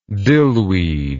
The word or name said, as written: dillweed